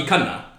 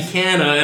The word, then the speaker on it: Khanna: Oh, but no one calls me, like, my real name, the way you say my name properly, Ravi Khanna. Right? Yeah, yeah, yeah. Khanna sounds so much better than Khanna, you know? Ravi Khanna.